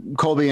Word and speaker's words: Colby: People call him Colby anyway. I mean, it's, you know, it's almost like a silent L.